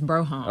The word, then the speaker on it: Brougham: Yeah, you gotta say it right too. It's Broham.